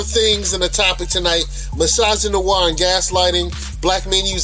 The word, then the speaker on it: misogynoir: And he talked about menage misogynoir. I think I'm saying that wrong. It's misogyny and noir. So misogynoir.